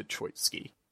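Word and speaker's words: Troicki: I I I want to just clarify. I'm pretty sure I say Victor Troitsky. – Yeah, I think we say that correctly.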